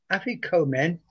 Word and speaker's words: Afikomen: Afikomen. A-F-I-K-O-M-E-N.